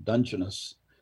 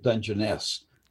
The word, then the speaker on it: Dungeness: Is it pronounced Dungeness or Dungeness? And apparently the British English and East Coasters pronounce it Dungeness, and we pronounce it Dungeness.